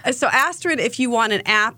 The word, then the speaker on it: Astrid: A-S-T-R-I-D. – Astrid. Yes. Not asterisk, but Astrid. It's like an asteroid and an asterisk that are mixed together.